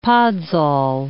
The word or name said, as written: podzol